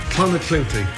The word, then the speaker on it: Clonakilty: Clonakilty, thank you.